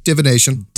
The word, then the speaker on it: Divination: Then in, Divination? Is that how you say it? – Divination. – Yep. – Good.